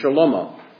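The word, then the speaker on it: shalomo (שלמה): The Hebrew pronunciation would be shalomo.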